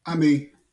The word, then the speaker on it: Ammi: Ammi.